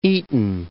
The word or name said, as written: Eton